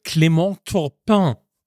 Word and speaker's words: Clément Turpin: Ganz kurze Frage, Clément Turpin. Das war der Schiedsrichter dieser Partie. Mhm. Ich hoffe, ihr habt das gehört, wie schön ich das ausgesprochen habe. – Das ist sehr schön.